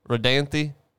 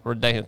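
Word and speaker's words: Rodanthe: This is a video of a house in Redanthi, North Carolina. – That is a good pronunciation right there. – Redanthi.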